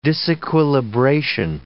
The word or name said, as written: disequilibration